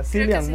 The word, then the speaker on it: Cillian: A mí me dijeron que es como pronunciar con K, pero bueno, ya. Ya, Cillian Murphy.